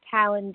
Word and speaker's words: Talyn: Talon D. – You know, this is awful. I'm sorry. I'm still not getting your first name. – I'll spell it. It's T-A-L-Y-N. – T-A-L-Y-N? – Yeah.